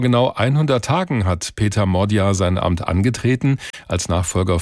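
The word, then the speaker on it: Magyar: Er schreibt sich zwar Peter Magia, also mit Y, spricht sich aber Modja aus.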